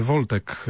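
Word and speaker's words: voldtekt: Bardzo podobnie to brzmi, natomiast w takim no, potocznym języku to brzmi prawie identycznie jak Wojtek, bo to się właściwie Woltek jakoś tak wymawia, to jest gwałt.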